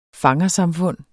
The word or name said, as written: fangersamfund